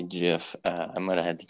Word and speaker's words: GIF: How do you pronounce G-I-F? – Oh no, it's GIF.